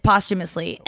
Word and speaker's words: posthumously: posthumously. It's posthumously, and that is stupid, and I agree with you on, like, it should be the way, and...